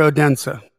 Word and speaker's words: Odense: Is it pronounced Odense or Odense? – Odense.